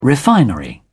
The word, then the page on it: refinery: British English